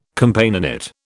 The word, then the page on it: Companionate: British English